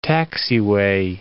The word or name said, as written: taxiway